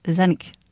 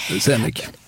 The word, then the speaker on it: Zanuck: Zanuck. Ja. – Zanuck.